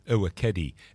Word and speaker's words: Ewekedi: Mbassi is spelled M-B-A-S-S-I. Coco Mbassi comes from a brand new album from her called Ewekedi. I'll spell that too. It's E-W-E-K-E-D-I, Ewekedi.